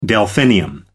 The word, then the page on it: delphinium: American English